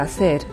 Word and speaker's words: hacer: で、アセールなんですけれども、テキストブログをご覧になっている方はもうお分かりかと思いますが、最初に発音されない H がついています。で、どういうふうに使うかっていうと、例えば ¿Qué vas a hacer esta tarde?、今日の午後、君は何をするつもり? なんていう時に使うんですね。¿Qué vas a hacer esta tarde?